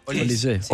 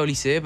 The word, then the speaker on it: Olise: Olise. Él lo pronuncia Olise, pero creo que se pronuncia Olis.